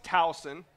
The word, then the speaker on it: Towson: UF plays Towson. That's Towson without an N.